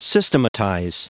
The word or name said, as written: systematize